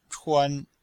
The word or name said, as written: chuan1